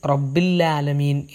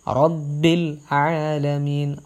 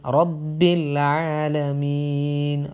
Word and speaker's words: رب العالمين: പലരും റബ്ബിൽ ആലമീൻ എന്ന് ഒറ്റ ഒഴുക്കിൽ പോകാറുണ്ട്. റബ്ബിൽ ആലമീൻ, റബ്ബിൽ ആലമീൻ, റബ്ബിൽ കഴിഞ്ഞിട്ട് ഐൻ വളരെ വ്യക്തമായിട്ട് ഓതാൻ ശ്രമിക്കുക.